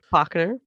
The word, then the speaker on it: Bochner: Bochner.